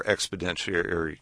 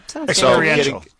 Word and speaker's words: experiential: So way, way more exponentiary. Learning. Did I say that right? Expeditionary? Yeah. – Experiential.